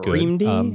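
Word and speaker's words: Reamde: Yeah, but, and of the recent stuff, Red Me, or however you're supposed to pronounce it, was Reamed-y.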